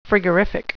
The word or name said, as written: frigorific